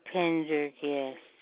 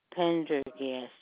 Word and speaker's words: Pendergast: Pendergast. That's like it sounds, huh? P-E-N-D-E-R-G-A-S-T. Pendergast. – Thank you. Can I get the first name? – Mm-hmm. Anne. A-N-N-E.